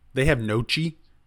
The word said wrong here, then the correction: gnocchi: That's not how you pronounce it. I hope you're kidding. – How do you pronounce it? – It's gnocchi. – Oh, I thought it was Nochi. – No, it's a gnocchi.